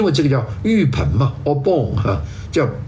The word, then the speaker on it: Obon: 所以我现在这搞清楚了，就是这个节呢，日本也过，日本呢不叫做盂兰节，日本叫做浴盆节，日文的读音呢叫做 Obon，浴盆这个正式的节日，日本在明治维新以后就改成一个正式的这个一个节日，是放假的啊。